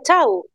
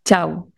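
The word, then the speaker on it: Chhau: Danza chau, como chau adiós que decimos nosotros. Así se pronuncia, danza chau. – Sí, chau.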